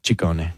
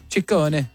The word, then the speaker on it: Ciccone: Well, in Italian, it works the other way. So the double C's are hard C's and the singular C's are soft C's. – So Ciccione. – Ciccone. – Ciccone. Madonna Ciccone.